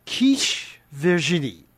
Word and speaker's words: quiche Virginie: Now, this is what I call, let's give it a little French pronunciation, quiche Virginie, or quiche Virginie. Quiche Virginie.